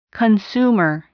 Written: consumer

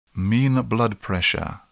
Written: mean blood pressure

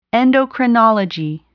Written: endocrinology

